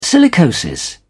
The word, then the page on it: silicosis: British English